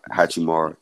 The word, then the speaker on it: Hachimura: Hachimura.